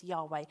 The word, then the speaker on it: Yahweh: And so over time, though, people have kind of started to pronounce it as, or not started to, but for a long time have pronounced it as Yahweh. And it's universally acknowledged now that that's sort of how it's pronounced.